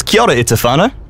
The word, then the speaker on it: kia ora e te whānau: Is kia ora e te whānau? – Mean, I'll make sure to remember that.